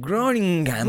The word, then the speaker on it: Groningen: Groningen.